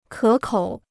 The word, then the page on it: 可口: kě kǒu